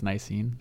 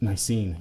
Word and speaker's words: Nicene: I'm pretty sure I'm butchering that. – I think it's Nicene. – Nicene, yes.